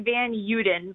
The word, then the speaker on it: Van Uden: It's actually Van Uden, but we pronounce it wrong. It's kind of the Americanized pronunciation. So you are closer to the Dutch original, so good work with that.